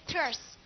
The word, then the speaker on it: terse: 那么咱们看下边的那个这个题目哈，咱们看下边的第四个选项，这单词什么意思啊？terse 哈，简洁的，是不是？这单词怎么记呀？你看它的发音 terse 好，是什么东西的简洁的说法？特务死去，简洁的说法叫特死。好，是不是啊？你看那发音是不是特死？